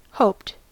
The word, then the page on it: hoped: American English